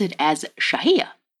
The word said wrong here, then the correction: Xiajia: Apparently, I was wrong, and it's pronounced... I'm going to play Brittany's soundbite here and not even going to try to pronounce it again. – Xiajia.